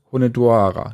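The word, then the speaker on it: Hunedoara: auf der äh, Burg Honedoara.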